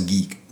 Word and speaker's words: GEK: So first of all, we call ourselves Geek, G-E-K, uh-huh. Good Eats Kitchen. It's probably supposed to be pronounced Geek, but we created it, so we say Geek.